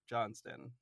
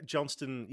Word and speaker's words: Johnstone: Is your last name pronounced Johnstone or Johnston? – It's kind of Johnston. Yeah, Johnston.